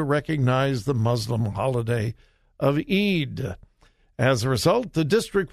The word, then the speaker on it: Eid: That's E-I-D in English. It's pronounced Eid. In Arabic, it's different.